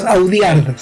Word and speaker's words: Audiard: A ver, esta es una película dirigida por un francés y se llama Jacques Audiard, así como odiar, pero es Audiard, se escribe.